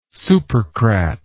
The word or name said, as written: supercrat